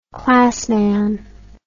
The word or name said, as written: classman